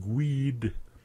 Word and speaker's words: GUID: manage disk space easily on MBR and GUID. G-U-I-D, group user ID. GUID. I love GUID. It's fun to pronounce it that way.